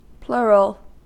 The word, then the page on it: plural: American English